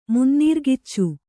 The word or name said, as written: ಮುನ್ನೀರ್ಗಿಚ್ಚು